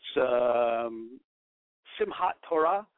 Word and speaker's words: Simchat Torah: How do you pronounce this? It's Simchat Torah, rejoicing in the Torah.